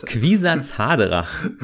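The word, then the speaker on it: Kwisatz Haderach: Quisatz Haderach.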